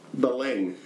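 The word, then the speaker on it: Belém: go ahead and look at how Belang is spelled. B-E-L-E-M. With some little hook on the ear. I don't know what the fuck. – Yes. – But it's pronounced Belang.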